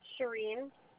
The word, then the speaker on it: Shireen: Shireen. – Okay. How do you spell that? – S-H-I-R-E-E-N.